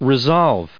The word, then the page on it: resolve: re*solve; American English